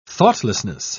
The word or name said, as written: thoughtlessness